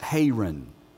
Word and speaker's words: Haran: Abram was 75 years old when he departed from, it's pronounced Haran.